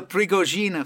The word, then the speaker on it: Prigogine: Another effect that you should go over is the Prigogine effect, if I'm pronouncing that correctly. – Oh, the Prigogine effect, yes.